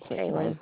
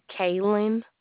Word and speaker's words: Kaylen: Kaylin. – Down. Pardon me? – Kaylin. – Kaylin? How do you spell that? – K-A-Y-L-E-N.